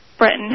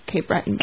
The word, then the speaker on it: Breton: I give it a French. – Yeah, you know, I'm probably saying it wrong. You know, I feel at this point my pronunciation is totally gone. I feel like if any of my students are listening, they're taking notes right now. Like, terrible pronunciation. But no, yeah, usually people say Cape Breton.